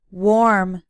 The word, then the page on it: warm: American English